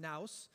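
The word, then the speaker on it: naos (ναός): Now, this word for, there's two different words for temple, and this particular word is, is pronounced naos, and what it refers to is the inner sanctuary.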